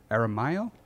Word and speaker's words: Aramayo: Uh, it stars James Badge Dale, Chris Mulkey, Brian Geraghty, uh, Robert Aramayo.